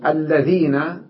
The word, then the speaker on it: الذين: قال: أولا نكتب أو تكتب الذين بلام واحدة، أو نكتب الذين بلام واحدة، وتلفظ بلامين لأنها مشددة، والحرف المشدد بحرفين، الأول ساكن والثاني متحرك، مثل مد دا بدالين، لكننا لا نرسمها إلا لاما واحدة، والشدة تدل على أن فيها لاما مدغمة.